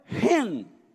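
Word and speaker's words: Ren (חן): E a pronúncia transliterada é Ren. Ren. É difícil falar. Não é ren, é ren. Que nem se você fosse quase. Então, ren. As duas letras aí Hit e Nun.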